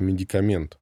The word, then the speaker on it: медикамент: «Потому что, говорится, медикамент с ударением на последнем слоге.